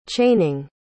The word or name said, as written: chaining